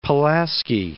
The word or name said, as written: Fort Pulaski National Monument